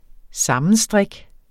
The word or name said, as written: sammenstrik